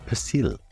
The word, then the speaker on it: persil: Parsley in French is pronounced Persil and spelt P-E-R-S-I-L.